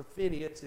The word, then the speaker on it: Phinehas: But as a young man, he was growing up, and the preacher's kids, Hophni and Phinehas, or Phineas, if you want to say it properly.